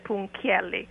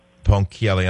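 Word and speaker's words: Ponchielli: I grew up in Italy, so in Italy we say Amilcare Punchielli.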